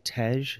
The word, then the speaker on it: Tej: Is it Tej, the Ethiopian honey wine? – Yep.